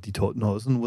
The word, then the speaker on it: Die Toten Hosen: Both of whom, might I point out, said that, that my pronunciation of Die Toten Hosen was, was perfectly good.